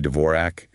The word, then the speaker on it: Dvorak: Dvorak.